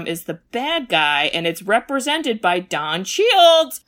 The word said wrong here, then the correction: Cheadle: I hope I'm saying his name right. – Do you mean Don Cheadle? Like, oh, Cheadle. Oh my god, Cheadle, yes.